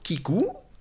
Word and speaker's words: Keeku: Vous aurez donc juste à aller sur Kikou, ça s'écrit K2EKU, e euh,